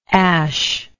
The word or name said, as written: aesc